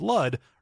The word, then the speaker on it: Lud: And boy, this is Lud, rhymes with crud.